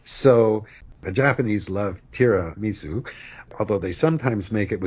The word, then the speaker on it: tiramisu: but it's tiramisu. – Right, and in the 80s, I think it was a big hit. You found it on every menu and often pronounced tiramisu. Tiramisu in Italian,